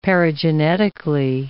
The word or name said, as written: paragenetically